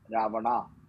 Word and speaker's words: Ravana: So Sanskrit, it is always Rama. It is Ravana. It is never Ravan.